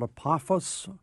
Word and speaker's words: Apophis: It's been getting a lot of hype, called Apophis, or Pothos.